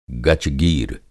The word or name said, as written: गचगीर